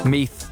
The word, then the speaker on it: Meath: Most difficult to pronounce county, Meath. Meath? Meath? Like beef? Meath. Newry, Dublin.